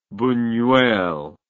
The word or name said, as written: bunuel